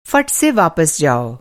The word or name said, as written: फट